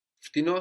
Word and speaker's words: φτηνό: Φτηνό has the accent on the end, so it's slightly different when we say cheaper.